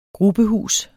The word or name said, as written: grubehus